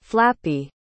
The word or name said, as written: flappy